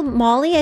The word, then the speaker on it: Maule: Molly, I think is how you say it. It's, God, I'll give you the address. – M-A-U-L-E.